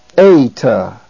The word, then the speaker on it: Eta: The second letter in this lesson is the letter Eta. Eta. It is the A sound, as in the word they. They.